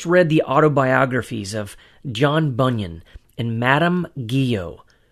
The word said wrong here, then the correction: Guyon: properly pronounced Guyon, I was encouraged by the fact that they too had suffered greatly for their faith in Christ.